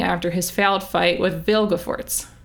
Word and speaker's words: Vilgefortz: Sorry, I said Vilgefortz with a lot of emphasis because I realized that I've been saying it wrong. I spell it right in my notes. I spell it right every time. But I've been putting the L after the G, like, in my pronunciation.